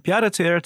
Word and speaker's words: pied-à-terre: The pied-à-terre tax. – You say it much better. Tell us about that. – And yet, I am not French. Well, that's the tax. So the pied-à-terre in French is a foot on the ground.